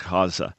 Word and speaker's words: Gaza: The only thing right was that they pronounced Gaza with its Arabic pronunciation, which is more like Haza.